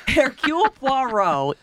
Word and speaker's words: Hercule Poirot: And between that and the fact that, so Hercule Poirot, I'm going to say it right once, Hercule Poirot is...